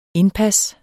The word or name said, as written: indpas